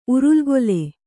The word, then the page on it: ಉರುಲ್ಗೊಲೆ: urulgole